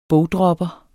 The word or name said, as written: bogdropper